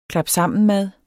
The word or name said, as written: klapsammenmad